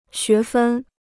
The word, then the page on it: 学分: xué fēn